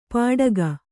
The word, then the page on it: ಪಾಡಗ: pāḍaga